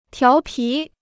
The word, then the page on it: 调皮: tiáo pí